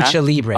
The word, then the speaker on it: Lucha Libre: Richmond Lubralice, Lubralice League, the Richmond Wrestling League. – Yeah, there was a loop. Yeah. – Lucha Libre. Lucha Libre. I was mispronouncing that.